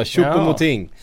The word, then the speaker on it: Choupo-Moting: Choupo-Moting? – Ja, Choupo-Moting.